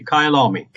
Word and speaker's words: Kyalami: Am I saying that right? Kyalami? – Absolutely. Kyalami. – Kyalami.